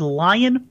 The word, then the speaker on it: Lyon: It, as far as I know, and the way that they announced it on the broadcast, it is Lyon Richardson. Lyon. L Y O N for those keeping score at home. – There you go, Lyon Richardson.